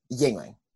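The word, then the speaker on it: Yuengling: Yingling.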